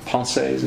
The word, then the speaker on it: Pensées: Blaise Pascal, in his book Pensées, is that how you pronounce it? – Yes.